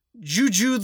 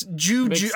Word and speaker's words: Gu Gu: The star of the zoo is Juju the giant panda. – Okay. – Now it's Juju. I think that's how you say it. It's just G-U, G-U. So Juju, right? – Okay.